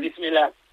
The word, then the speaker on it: Bismillah: Una cosa que me dijo Ilias Akomaj, a ver si la pronuncio bien, Bismillah. – Eso es. – ¿Lo he dicho bien? – Bismillah. Sí, muy bien, muy bien.